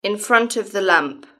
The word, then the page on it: in front of the lamp: British English